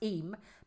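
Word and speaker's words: Eyam: Well, Liz Danziger has messaged and said, I pronounced it I am. She said it's pronounced Eam.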